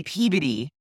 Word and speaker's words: Peabody: Only you Massachusetts people say Peabody.